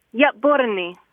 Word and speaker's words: Yatborni: After the yat, there's kind of a little symbol if you see it written, and it kind of means a kind of a harsh stop. – It catches in your throat, kind of. – Yeah, a little bit. So like Yatborni, like a B-O-R-N-E is maybe how I would spell it phonetically. Like Y-A and then kind of a little apostrophe, like a stop, and then B-O-R-N-E, like Yatborni.